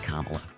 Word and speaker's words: Kamala: Except, like, it's pronounced Kamala.